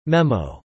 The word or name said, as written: memo